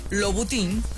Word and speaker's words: Louboutin: De Louis Vuitton, o como lo quieran mencionar ustedes, Louboutin, que es como la pronunciación real.